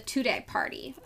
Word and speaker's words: Tudeh: That's called the Tudeh Party, I believe is how it's pronounced. It's T-U-D-E-H.